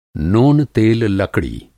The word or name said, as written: नोन-तेल-लकड़ी